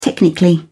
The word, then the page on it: technically: British English